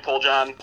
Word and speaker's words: Poljan: Is that how you pronounce his name? – Yeah, Tony Poljan.